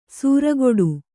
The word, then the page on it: ಸೂರಗೊಡು: sūregoḍu